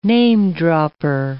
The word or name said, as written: name-dropper